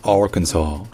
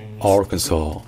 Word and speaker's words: Arkansas: Alors que dans Arkansas, l'accent tonique est sur le R, donc c'est Arkansas, Arkansas. Et donc le KN dans l'Arkansas, il n'est pas du tout accentué. C'est juste un KN. En fait, on n'entend presque pas la voyelle.